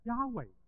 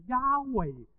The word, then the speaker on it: Yahweh: 因为后来我们再研究的时候，其实整个发音应该是 Yahweh，是 Yahweh 才是那个发音，但是犹太人因为基于严守这个诫命，不可妄称神的名字的时候， Yahweh，你会问他，哎， Yahweh 啱唔啱？